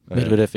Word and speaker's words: Medvedev: fordi selvfølgelig er det et hårdt skæbne for Medvedev, jeg kan ikke engang udtale hans navn. – Medvedev, ja.